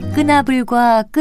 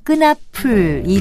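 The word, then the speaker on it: 끄나풀: 다른 사람의 앞잡이 노릇을 하는 사람을 낮잡아 말할 때 끈아불과 끈아풀 이 두 가지를 모두 들을 수 있습니다. 그렇다면 표준어는 어떤 것일까요? 끈아풀, 이 거센 소리로 쓰는 끈아풀이 표준어로 되어 있습니다.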